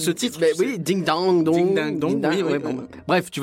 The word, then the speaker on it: Ding Dang Dong: Ding dang dong. Bah ça s'écrit ding dong dong. Mais c'est ding dang dong.